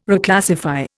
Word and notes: Reclassify: Indian

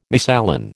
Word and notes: misalign: American English

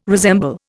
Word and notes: resemble: Indian

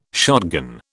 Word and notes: shotgun: British English

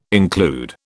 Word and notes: include: British English

